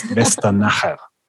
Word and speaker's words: Westernacher: Yeah, the correct pronunciation, and I'm absolutely sure it's very difficult for a non-German speaker, is Westernacher. – Westernacher. – Westernacher.